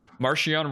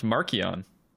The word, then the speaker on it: Marcion: Marcion Rowe, pronounced Markion. – Ooh. – I like Marcion better. – Yeah. – Charles Soule was talking about, because he created the character, right, for Light of the Jedi, and he was saying his name, and he said Markion.